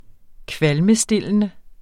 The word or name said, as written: kvalmestillende